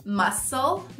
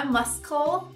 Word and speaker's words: muscle: O C mudo a gente encontra na palavra músculo, muscle, não é muscle, muscle, tesoura, scissors, scissors.